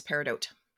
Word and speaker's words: Peridot: Yeah, so there are three acceptable pronunciations of the word, and I use Peridot.